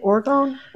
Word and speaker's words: orgone: Tell me about how you use orgone. Is that how to say it? Orgone? – Yeah. Orgone. Yep.